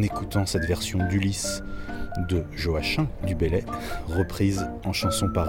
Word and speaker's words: Joachim: au prénom dont la prononciation ne met pas tout le monde d'accord, mais moi, comme tout angevin qui se respecte, j'honore le poète de ma région en prononçant son prénom Joachim, et pas Joachim, mais en réalité les deux sont admis.